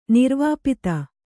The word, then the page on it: ನಿರ್ವಾಪಿತ: kannada